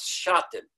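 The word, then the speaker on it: Husiatyn: And there was one that is pronounced in Yiddish as Shatin. Shatin the Hasidim, the Shatin the Rebbe. It's spelled in Hebrew and in English as if it should be pronounced Husiyatin, Hushiyatin, Shiatin the Rebbe.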